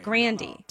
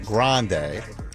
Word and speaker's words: Grande: I mean, we can only pronounce it the way you tell us to pronounce it. And she's been telling us to pronounce it Grande.